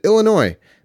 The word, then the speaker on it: Illinois: In Illinois, we pronounce it Illinois.